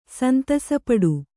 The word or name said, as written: ಸಂತಸಪಡು